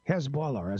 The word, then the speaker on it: Hezbollah: Hezbollah, as I pronounce it, and that's the way they pronounce it, by the way, a scandal.